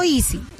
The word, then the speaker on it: Easy: Easy. Entonces, voy a decirlo, se escribe EASY. ¿No? – EASY. – EASY con Y al final.